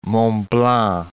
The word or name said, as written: Mont Blanc